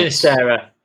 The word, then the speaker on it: Sinisterra: Sinisterra.